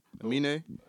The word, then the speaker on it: Amine: A-M-I-N-E with a... – I don't know. – Amine? I don't fucking know. Amine? – Amine. That's the dude with the Caroline song.